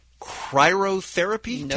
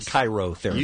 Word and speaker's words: cryotherapy: cryotherapy tank? – Not even. – Nope. – I think it's just cryo. Cryotherapy, and I said chirotherapy.